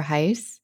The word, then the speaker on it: Heisz: And I'm on Twitter at Jennifer Heiss. And my last name is spelled H-E-I-S-Z.